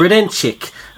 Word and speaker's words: Gredenchik: Is it Grodenchik? – way to pronounce it. Gredenchik.